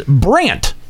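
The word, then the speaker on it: Brandt: My next selection is Brant.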